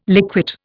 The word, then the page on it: liquate: Indian